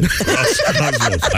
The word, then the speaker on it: Roscosmos: Russia's space agency, Roscosmos. Is that right? R-O-S. Yeah. Cosmos. – You say it right or you're dead. – Roscosmos.